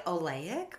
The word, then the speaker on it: oleic: Okay, I was looking at my sunflower butter, and it said high oleic oil. Did I say that right? – Yeah.